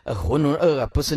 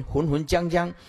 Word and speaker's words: 浑浑噩噩: 因为我小学的时候看到第一次看到这个浑浑噩噩，不是念这样念，我混把它念成浑浑浆浆啊，老师啊笑到半死了啊，这浑浑浆浆看起来像新疆的浆，是不是啊？